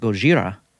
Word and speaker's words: Gojira: That original 1954 Japanese film, spelled differently and pronounced Gojira, came less than 10 years after America dropped atomic bombs on Hiroshima and Nagasaki.